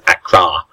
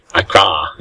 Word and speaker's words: Accra: As I've learned how to say it now, is Accra. – Accra.